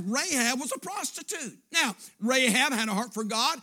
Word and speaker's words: Rahab: That's in the Old Testament pronounced Rahab.